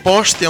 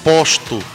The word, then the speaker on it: poste: Devemos evitar uma pronúncia da linguagem informal que diz posto. Essa palavra, posto, não existe. O correto é dizer poste.